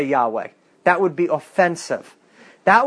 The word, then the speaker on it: Yahweh: And the scholars believe it would be pronounced Yahweh.